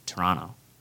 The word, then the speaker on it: Toronto: He makes sure he's emphasizing the T's. – Well, that's how it's pronounced. It's Toronto.